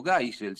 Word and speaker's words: Geisel: El Brasil dictatorial, presidido por el general Ernesto Geisel, o Geisel, si quieren la pronunciación, hizo comenzar a Brasil un camino sin retorno.